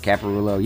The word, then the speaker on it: Caparulo: Am I pronouncing that right? Caparulo? – Yes. – Italian? – Yes, Caparulo. Yeah.